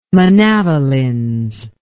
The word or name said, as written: manavelins